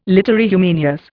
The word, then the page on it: literae humaniores: Indian